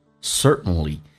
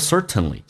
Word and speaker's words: certainly: Next word, certainly, certainly.在美式发音当中 T 跟 N 组合在一起，通常 T 可以不用发音，阻塞一下气流， certain, certainly，所以你会说 certainly，这也是对的，只是口语化当中经常会说成 certainly, certainly，一定。